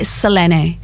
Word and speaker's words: Selene: Well, I don't know that I'm going to pronounce it perfectly, but it would be Selene. – Selene. – But with a bit of a UK and Oz twang, we say Selene.